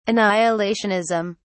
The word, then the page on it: annihilationism: British English